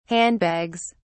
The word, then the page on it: handbags: British English